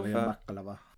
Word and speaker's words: Bakalova: Maria Bakalova.